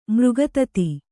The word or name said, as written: ಮೃಗೋತ್ಥ